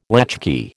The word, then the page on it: latchkey: American English